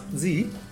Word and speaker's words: zi: Okay, so a zi, pronounced zi, is a Tibetan word used to describe a pattern.